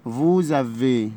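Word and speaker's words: vous avez: فوزافي، فو يعني انتم او حضرتك، هننطق الاس برضو ننطقها زد. فوزافي حضرتك لديك او انتم لديكم.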